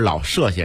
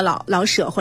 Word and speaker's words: 老舍: 嗯，我发现一细节，您刚才跟我们说是老舍先生，跟我们说的不一样。您跟我们这个应该是怎么读？ – 这个我以前也研究过哈，好像大家有的有争论哈。 – 嗯。 – 好像现在是这样的一个结论，两个读音都可以，就是因为他是。为什么是叫老舍或者是老舍，